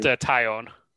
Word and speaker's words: Taillon: How do you pronounce it? – I think it's pronounced Taillon.